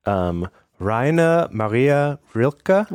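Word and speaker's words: Rainer Maria Rilke: Raina Maria Rilke.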